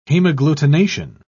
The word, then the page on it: hemagglutination: British English